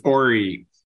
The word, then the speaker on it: Horry: Is that it is, Robert Horry or Horry? – It's Horry. – Okay. Horry. Two of you guys are saying Horry.